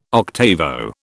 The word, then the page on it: octavo: British English